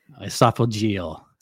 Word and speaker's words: esophageal: Esophageal, okay. I've been saying that wrong for like 3 months.